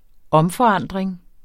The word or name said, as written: omforandring